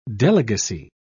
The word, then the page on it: delegacy: British English